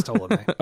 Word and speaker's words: Ptolemy: Yeah, it took me a minute to pronounce it when I first read it, but I'm pretty certain that's Ptolemy.